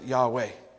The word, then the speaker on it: Yahweh: In Hebrew, the name of God is spelled YHWH. You see, the Hebrew, ancient Hebrew, had no written vowels, and most scholars pronounced it Yahweh.